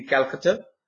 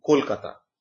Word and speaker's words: Kolkata: As I said, the word Calcutta, because British used to name my city Calcutta. But now we have changed to the Bengali pronunciation to Kolkata. So even the spelling got changed and the pronunciation got changed.